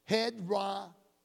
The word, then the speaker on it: hedraios: It's spelled H-E-D-R-A-I-O-S. And it's pronounced hedrazo.